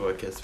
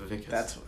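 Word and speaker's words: Wikus: It's spelled Wickus, but it's pronounced Vickus.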